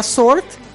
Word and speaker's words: sword: Por ejemplo, la palabra sort, que se escribe S W O R D, no se pronuncia la W porque es sorda o en este caso es muda. Sort.